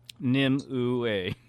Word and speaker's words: Nimue: Nimue. – N-I-M-U-E.